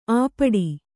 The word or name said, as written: ಅಪ್ಪಟೆ